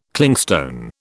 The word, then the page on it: Clingstone: British English